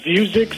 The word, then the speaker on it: Vuzix: Well, the stock I'm interested in, Jim, is I believe it's pronounced Vuzix, V-U-Z-I-X, symbol V-U-Z-I.